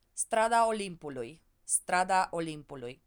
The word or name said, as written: strada Olimpului